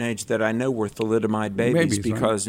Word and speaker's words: thalidomide: Yeah, thalidomide.